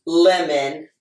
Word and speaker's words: Lemon: It's Kelly with an I, Lemon, like the fruit.